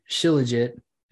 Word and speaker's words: Shilajit: So I got Reishi, Shilajit. Am I saying that right? – Yeah. That's how I call it.